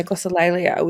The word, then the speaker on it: glossolalia: It's interesting to me because I mean, in the psych world, we call it glossolalia, I think, is how you glossolalia, I think that is how you pronounce it. – Yeah, glossolalia, which is just speaking gibberish.